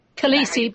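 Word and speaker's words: calici: He actually tested positive for Kalisia virus. I think that's how you pronounce it. – Khaleesi, but yes, close enough. – Khaleesi virus, thank you.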